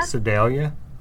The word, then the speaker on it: Sedalia: So, but I think they pronounced it Sedalia? – Sedalia. – Start with an S, Sedalia? – That's right.